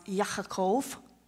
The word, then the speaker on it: Yaakov: In Hebrew, it's pronounced Yaakov.